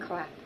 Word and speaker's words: Clapp: Clapp. – Yeah. She was C-L-A-P-P.